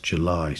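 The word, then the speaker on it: JVLY: It comes from the Newcastle-based artist July, spelt with a V, J-V-L-Y.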